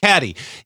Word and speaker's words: CADDi: Caddy.